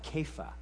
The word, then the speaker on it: Kepha: And the way they describe Cephas, which I always say Cephas, they call it Kepha. Kepha is a very interesting pronunciation, which when translated means Peter.